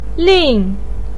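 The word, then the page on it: lin4: pinyin; tone 4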